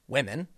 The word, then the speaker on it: Womxn: Wimixen Okay, you, you heard me right. Wimixin. W O M X N. It's supposed to be pronounced women, I guess.